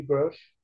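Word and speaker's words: Grusch: Vieram a público como David Grush, que é assim a pronúncia do sobrenome dele.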